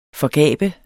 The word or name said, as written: forgabe